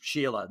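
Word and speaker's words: Shelagh: So, and if you see it, it's spelled S-E-S-H-E-L-A-G-H, but she's Sheila. That's how you pronounce that.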